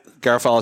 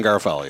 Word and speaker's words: Garofalo: Yeah, Garofalo is how we say. You can drop the third, but John is good. John Garofalo, yeah.